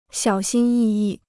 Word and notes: 小心翼翼: xiǎo xīn yì yì

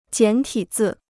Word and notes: 简体字: jiǎn tǐ zì

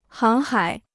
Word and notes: 航海: háng hǎi

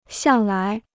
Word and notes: 向来: xiàng lái

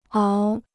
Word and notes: 熬: áo

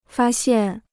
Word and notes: 发现: fā xiàn

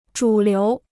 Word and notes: 主流: zhǔ liú

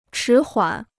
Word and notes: 迟缓: chí huǎn

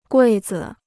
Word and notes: 柜子: guì zi

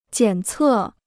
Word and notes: 检测: jiǎn cè

